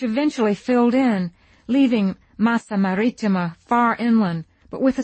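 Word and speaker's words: Marittima: The accent I find is on Maritima. I've said Maritima. Will I ever, ever learn Italian?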